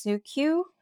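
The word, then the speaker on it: Suqqu: Right now, the sunscreen that I'm in love with is by a brand, I think it's pronounced Suqu. It's S-U-Q-Q-U.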